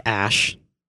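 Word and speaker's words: Æ: It's like Ash. – Well, like part of it is Ash. Like the AE, that's Ash.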